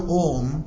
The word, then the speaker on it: Om: So here, this letter Om, as I mentioned before, it has got three parts, you say, A, U and Ma. A, U and Ma. So, when you pronounce the letter Om, that is, these three letters are combined together. Om.